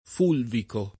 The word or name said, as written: fulvico